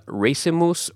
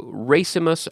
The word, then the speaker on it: racemus: It comes from the Latin word racemus or racemus, racemus. I'm not really good at pronouncing Latin, but it's R-A-C-E-M-U-S.